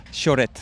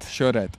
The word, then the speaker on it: Xorret: The pronunciation of X's in the local dialect, Xorret? – Xorret. – Xorret. Yes.